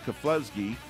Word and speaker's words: Keflezighi: That's how you say it, Keflezighi.